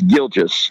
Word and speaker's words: Gilgeous: And by the way, I'm gonna help you out here, Evan, because it's Gilgeous. Because I mispronounced his name all year too. It's Gilgeous Alexander.